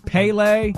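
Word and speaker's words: Pelé: Pele. – Pay, like P-A-Y-L-A-Y. – Pele. – Or Pele. How would you? – P-E-H? – P-U-H? Yeah, P-U-H.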